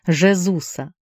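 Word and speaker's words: Jesus: В 66 деревянных фигурах художник воплотил 7 сцен, изображающих крестный путь Жезуса. Так по-португальски произносится имя Иисуса.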